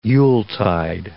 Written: yuletide

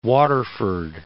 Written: Waterford

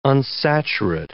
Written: unsaturate

